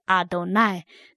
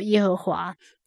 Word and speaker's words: 耶和华: 我们念希伯来文圣经还是念作阿多奈，那至于中文圣经，我们还是念作耶和华，但是我们要知道它本来不是这个音也就可以了，因为真正爱戴神敬畏神的是心灵，并不是外表上的一些动作。